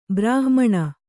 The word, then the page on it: ಬ್ರಾಹ್ಮಣ: Kannada